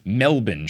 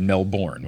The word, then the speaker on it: Melbourne: The subject line is Melbourne Show, M-E-L-B-I-N. When I had first said that I was going to try to come to Melbourne, I had said Melbourne, which I know is very wrong.